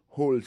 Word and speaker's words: whole: que vous prononcez E en anglais, hole, c'est W-H-O-L-E, lat.